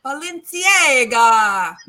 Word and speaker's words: Balenciaga: Well, let me say it like the Italians say, Balenciaga.